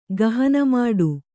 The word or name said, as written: ಗಹನಮಾಡು